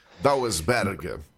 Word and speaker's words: Dauelsberg: Dauelsberg. – Dauelsberg. E a sílaba tônica ainda é a primeira, entendeu? Então é proparoxismo.